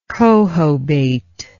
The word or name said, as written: cohobator